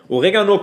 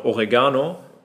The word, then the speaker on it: Oregano: Oregano kennt man hier eigentlich nur aus, ah, die meisten in Deutschland sagen auch noch Oregano, es wird Oregano ausgesprochen, wird jeder äh, Italiener bestätigen können.